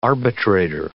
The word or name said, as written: arbitrator